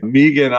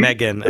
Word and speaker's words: Megan: I just feel good that now I get to say chapter 39, Pandemonium, written by Megan Amram and Jen Statsky. – Megan, I think. – Oh, Megan. See, then we'll find out. See, I got to give her credit, but I said her name wrong.